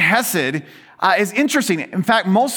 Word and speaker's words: Hesed: That word Hesed, and sometimes you'll see it spelled with an H, sometimes you see it spelled with a ch, the CH, right? Because most Hebrew words have that ch. So if you just start choking, you're probably speaking Hebrew.